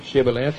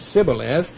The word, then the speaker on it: Shibboleth: Then he said unto him, "Say now Shibboleth." And if he couldn't say Shibboleth, he said Sibboleth. He could not frame to pronounce it right.